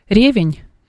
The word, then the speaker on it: ревень: Саша, зум, я сейчас могу неправильно поставить ударение. Ревень? – Ну да, ребеневое варенье, да.